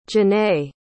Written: Janay

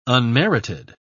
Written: unmerited

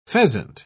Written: pheasant

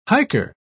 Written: hiker

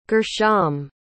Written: gershom

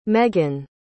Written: meggan

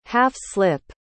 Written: half-slip

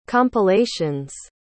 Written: compilation's

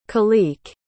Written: khaliq

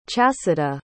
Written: chasidah

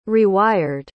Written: rewired

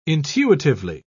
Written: intuitively

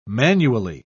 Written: manually

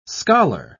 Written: scholar

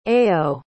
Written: ayo

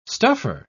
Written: stuffer

